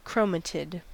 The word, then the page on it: chromatid: American English